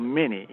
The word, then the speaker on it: Many: That's spelled Manny, M-A-N-Y, but they call it Manny, Louisiana.